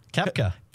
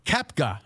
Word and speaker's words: Koepka: Koepka. – Koepka, okay.